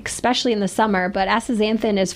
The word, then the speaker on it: Astaxanthin: But we want to talk about a product called Astaxanthin, which is say that 12 times I was going to say, well done on the pronunciation on that one. – Astaxanthin.